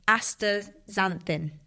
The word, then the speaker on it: astaxanthin: And then finally, my golden crown, there's a supplement called, I can never pronounce it properly, it's the worst one for me, astaxanthin. – Astaxanthin. – Did I say it right? – Yeah.